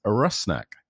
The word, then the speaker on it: Rusnak: So that you can all be better prepared for my goal-scoring announcements this season, the pronunciation of our new designated player's name is Rusnak, Albert Rusnak.